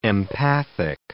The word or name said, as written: empathic